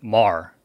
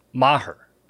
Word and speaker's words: Maher: No, it's not pronounced Maher. It's pronounced Maher. – Right. Like, I'm going to martyr you after the show.